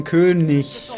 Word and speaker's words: König: Was ich sagen wollte, im Gegensatz zu anderen bin ich kein ARD-Sprecher und weiß nicht, wie man König richtig ausspricht. – Das war sehr gut. Das ist ein Ich-Laut im Gegensatz zum Dach.